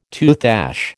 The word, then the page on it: toothache: American English